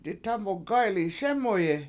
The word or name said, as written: ditamugAyPliSEmuyi